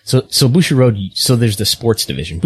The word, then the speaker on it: Bushiroad: And we will go from Mexico to Japan, and, the finances of, Bushiroad. Did I pronounce that correctly? – Bushiroad? – Okay.